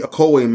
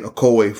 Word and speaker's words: Ocoee: Ocoee, right, exactly. Get the right pronunciation. The Ocoee Massacre in 1920.